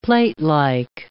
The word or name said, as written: platelike